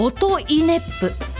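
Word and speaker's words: 音威子府: というわけで、音に威厳の意に子供の子、大阪府京都府などの府、音に威厳の意、子供の子、京都府などの府で、皆さんこれ何と読むでしょう。音イネップと読むそうですよ。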